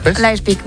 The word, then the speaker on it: Leipzig: Leipzig. ¿Lo he dicho bien? – Qué importante saber idiomas. – Tengo aquí a mi amigo que me lo dice. ¿Cómo se dice? Leipzig. Leipzig. – Leipzig, muy bien.